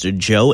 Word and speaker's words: Djo: It took me way too long to realize that it is pronounced Joe and not just D-J-O.